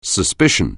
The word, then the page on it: suspicion: anglais